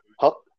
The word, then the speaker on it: haq: Huck, huck.